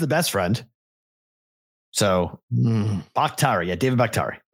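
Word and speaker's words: Bakhtiari: Somebody put the phonetic spelling. Buktari.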